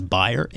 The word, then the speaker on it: Bayer: Because I think that as Americanized as we are, I believe the correct pronunciation is Bayer.